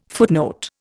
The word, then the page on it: footnote: Indian